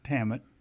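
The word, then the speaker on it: Tannat: One of them, in this particular bottle, and they're really dramatic bottles, I think, but it says it's 67% Merlot and 33% Tamit. Is that how to say it? – How what? – Tamit? No, it's Tamit.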